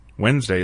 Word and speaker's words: Wednesday: Wednesday is just one example of words like February or ptarmigan, where letters appear in a word's spelling, but not in its pronunciation.